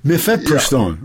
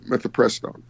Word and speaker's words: Mifepristone: But Mifepristone, right. – Mifepristone.